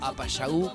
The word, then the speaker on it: Apayagu: Apayagu con acento en la U.